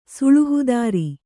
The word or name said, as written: ಸುಳುಹುದಾರಿ